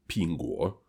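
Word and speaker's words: пинго (苹果): Яблоко, которое они дарят друг другу, на китайском языке звучит как пинго. То есть можно заметить пинг анье, пинго. Произношение пинг одинаковое.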